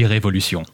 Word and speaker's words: Révolution: Loving art from the past and present, I've been checking out Revolution, or is it Revolution? Which one is it? – Technically, you can say both, but the way it's spelled is the French way, so it would be Révolution.